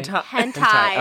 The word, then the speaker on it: Hentai: Okay, so my video was called Real Life how do you say Hentai. – Okay, ready? Let me do it again. – Like a very popular porn thing. – Yes, it is. – Real Life Hentai.